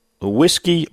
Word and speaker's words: Ouiski: And a little while ago, we had, it's spelled O-U-I-S-K-I. Whiskey Bayou Boy. And I didn't catch the whiskey pronunciation. So Cousin Carl texted back in and said that's the way it's pronounced. Whiskey Bayou.